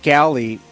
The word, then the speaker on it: Galli: Mark Galli, as in a ship's galley, is my guest. That's how he helped me remember his name or to pronounce it correctly, G-A-L-L-I.